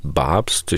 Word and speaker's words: Babst: Stefanie Babst heißt sie, also wirklich Babst. Ich habe es jetzt nicht sächsisch ausgesprochen. Papst mit B wie Berda am Anfang.